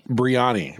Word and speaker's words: biryani: The shrimp biryani? – Biryani. Yeah. Biryani, like brie, but ani.